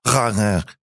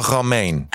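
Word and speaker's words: Romijn: Her name is, it's, I mean, here in the United States, they say Romaine, but I learned in Holland, where my wife is from and her people are from, it's actually pronounced Rane. – Oh. – I'm kidding. I really exaggerated that. Everyone, you guys thought it was real. It's pronounced Rebecca Romaine. – Romaine. – Romaine. That's how they say it.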